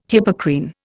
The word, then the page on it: Hippocrene: Indian